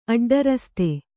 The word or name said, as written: ಅಡ್ಡರಸ್ತೆ